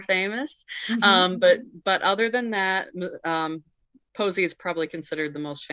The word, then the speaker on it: Posey: Yes, Alex Posey.